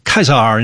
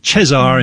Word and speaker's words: Caesar: You know, it's pronounced Caesar in classical Latin and Cesar in medieval or church Latin.